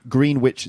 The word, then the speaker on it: Greenwich: Greenwich, it's actually pronounced Greenwich.